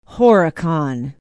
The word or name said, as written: horicon